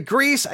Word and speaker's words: Griess: Now, Griess is spelled G-R-I-E-S-S. And of course, I did not know how to say it correctly. But luckily, one of the listeners emailed me and told me the correct way. All right. Pastor Corey Grease, again, the cure for talebearing, the cure for talebearing.